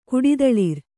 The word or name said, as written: ಕುಡಿದಳಿರ್